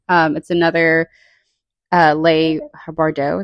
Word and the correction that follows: Bardugo: Leigh Bardugo. That's how you say it.